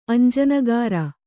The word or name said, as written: ಅಂಜನಗಾರ